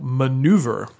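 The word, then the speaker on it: Manoeuvre: And we're going to be, as part of that, reviewing a interesting game from GMT Games called Maneuver. It's got some additional vowels in there, but yeah, it's pronounced maneuver.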